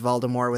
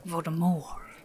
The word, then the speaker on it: Voldemort: Yeah, because he pronounces a lot of things correct, like Knuts, and in the first two audiobooks, he pronounces Voldemort without the T. It's not until, like, the movies start coming out that they changed that one. – Voldemort. – Yeah, this is French.